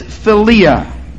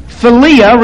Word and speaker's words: philia: So the first one is eros, the second one is philia, with the emphasis on the second syllable. Philia, all right? Now, eros relates to the body. Philia relates to the soul, the personality.